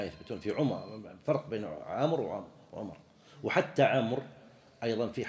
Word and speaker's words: عمرو: بل في العربية حين حروف تنطق ولكن لا تكتب، شوف تنطق وما تكتب، مثل عمرو بعض الناس يقول عمرو يحط واو، هي ليست كذلك.